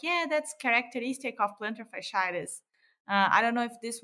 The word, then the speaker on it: fasciitis: And what I think is interesting on the third case, when you're describing the plantar fasciitis, oh my gosh, I have problems with this word, fasciitis. How do you say it? – Fasciitis. – Fasciitis.